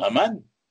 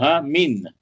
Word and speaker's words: Haman: Haman, ça s'écrit He Mem Nun Finat. Alors, bon, c'est très connu. On peut le He Mem Nun Finat, on peut le lire Haman, mais on peut aussi le lire Hamin.